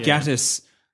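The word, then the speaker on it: Gatiss: is it Gattis? – Mark Gattis.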